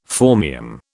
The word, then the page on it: phormium: British English